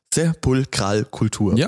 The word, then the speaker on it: Sepulkralkultur: Ich habe das jetzt richtig ausgesprochen. Sepulkralkultur, oder? Sagt man schon so.